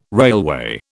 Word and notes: railway: British English

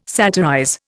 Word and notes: satirize: Indian

